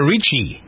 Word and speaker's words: Ricci: Christina Ricci.